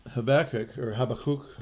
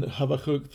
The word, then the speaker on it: Habakkuk: Shirley and I, in our morning readings the other day, were in the book of Habakkuk is the Hebrew pronunciation, I believe. I'm not totally perfect on my pronunciation, but Habakkuk is considered one of the minor prophets, and I'm going to read you a little note from F.B.